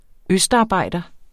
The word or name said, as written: østarbejder